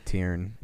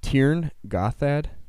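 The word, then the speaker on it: Tyrn Gorthad: The Barrow Downs or the Trine Gothard, not Gothard. – Yeah, Tirn.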